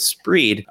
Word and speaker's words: Spreed: It's an extension called Spreed, which is tough to say, so I'm going to spell it also. S-P-R-E-E-D, Spreed. It's a sort of like a combination of the two words speed read, because that's what it's for.